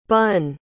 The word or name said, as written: Bunn